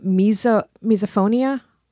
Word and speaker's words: misophonia: but this whole misophonia thing. Am I saying that right? – Oh, yes.